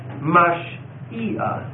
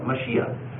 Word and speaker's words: Mashiach: that's how you pronounce it, Mashiach, and Messiah in English.